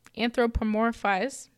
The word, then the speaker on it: anthropomorphize: Um, but um, so humans tend to anthropomorph. Okay, anthropomorphize. – Uh-huh. I think I uh-huh. missed a syllable.